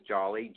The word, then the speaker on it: Jolley: Spell your last name for everyone because they may not, it sounds like it's just spelled like Jolly, but it's not. So would you explain to everybody? – Sure. My last name is Jolly, J-O-L-L-E-Y.